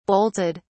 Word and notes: bolted: British English

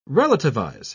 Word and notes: relativize: British English

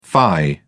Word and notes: fie: British English

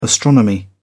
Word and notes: astronomy: British English

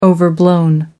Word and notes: overblown: American English

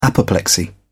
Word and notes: apoplexy: British English

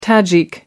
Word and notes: tajik: American English

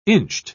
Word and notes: inched: British English

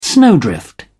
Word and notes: snowdrift: English; British English

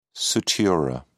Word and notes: sutura: British English

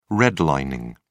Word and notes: redlining: British English

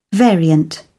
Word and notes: variant: British English

